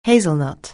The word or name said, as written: hazelnut